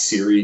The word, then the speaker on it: CIRI: Under the old approach that everybody took to data sets like the Political Terror Scale or the Sanguinelli and Richards CIRI, it's usually pronounced CIRI data,